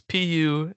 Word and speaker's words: Puddicombe: But so I found this guy, and I'm probably gonna butcher his name, Andy Pudicombe, Pudicombe, Pudicombe. I don't know. you can Google him. It's Puddicombe.